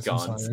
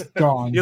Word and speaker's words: Gans: I'm gonna butcher this. Yeah, it's Gans. – It's Gans.